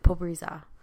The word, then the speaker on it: Pobreza: Maldita Pobrezo. – That's an A. – We keep moving it. Pobreza. – So I was right.